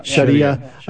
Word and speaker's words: Sharia: you apply the idea of Sharia law to Sharia, as you guys pronounce it, rolling the R, Sharia.